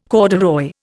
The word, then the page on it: corduroy: Indian